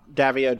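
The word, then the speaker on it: Daviau: So, like, Rob Davio did this, I'm pronouncing his last name, right? – Yep, you are.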